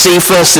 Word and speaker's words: Cephas: When Cephas came to Antioch, as some have heard that pronounced Cephas, it's Peter is who's being talked about here.